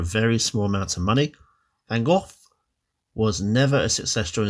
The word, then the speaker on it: van Gogh: We have an artist as our next failure turned success with Vincent van Gogh. Now, I know it's said right because I just looked on YouTube for the pronunciation.